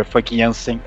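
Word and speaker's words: Famke Janssen: Funke Jansen. Eu descobri que pronuncia assim o nome dela.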